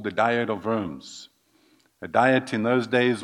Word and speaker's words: Worms: Pronunciation in German is Worms.